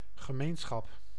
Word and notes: community: American English; /k(ə)ˈmjunəti/; /k(ə)ˈmjunəɾi/

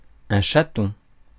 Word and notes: chaton: /ʃa.tɔ̃/

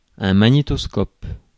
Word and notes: magnétoscope: France; [ma.ɲe.tɔs.kɔp]

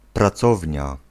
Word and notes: atelier: /a.tə.lje/

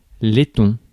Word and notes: Letton: lɛ.tɔ̃

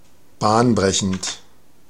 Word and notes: bahnbrechend: [ˈbaːnˌbʁɛçn̩t]

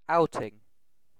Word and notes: outing: IPA: [ˈaʊ.tɪŋ]; British English

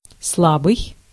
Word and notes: soft: American English; [ˈsɑft]

